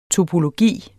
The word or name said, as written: TOPOLOGI